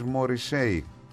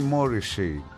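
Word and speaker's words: Morrissey: Θα μου πείτε γιατί τον λες Μόρισι και δεν τον λες Μόρισέι. Γιατί μπορεί να γράφεται Στίβεν Πάτρικ Μόρισέι, αλλά η προφορά του επωνύμου του, έτσι μου είπαν από το Μάντσεστερ κάτι φίλοι, θα τον λες, μου λέει, Μόρισι.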